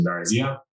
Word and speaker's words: Gbarazia: So yeah, follow me on LinkedIn and just my first and last name, Brighton Gbarazia. Um, and again, Gbarazia is spelled G B A R A Z I A.